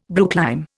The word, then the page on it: brooklime: Indian